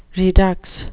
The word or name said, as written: redux